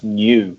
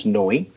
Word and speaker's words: Neu: I used to think that their name was pronounced New. It's the band from Germany that they first put out their albums in the early 70s, and you spell their band name N-E-U, but it's definitely pronounced Noi.